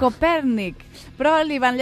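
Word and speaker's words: Kopernik: D'entrada no es deia Copèrnic, perquè era polonès, ella era Nicolaus Copernik, però bé, sentim-ho pronunciat per una noia polonesa que ha tingut, doncs mira, el detall de fer-nos una nota de veu.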